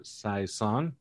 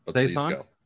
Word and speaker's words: saison: Their saison, right? Is that pronounced correctly? – Not even close, but saison. Saison. – Yeah, that'll work.